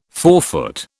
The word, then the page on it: forefoot: British English